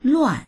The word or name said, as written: luan4